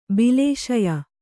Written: ಬಾಲಶಯ್ಯೆ

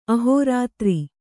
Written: ಅಹೋರಾತ್ರ

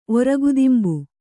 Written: ಒರಗುದಿಂಬು

